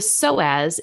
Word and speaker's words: psoas: but the SOAS is a muscle and it's spelled P-S-O-A-S.